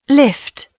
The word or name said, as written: lift